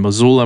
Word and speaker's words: Missoula: Now, Alex and Libby Metcalf are our interview guests today, and they are professors at the University of Montana in Missoula.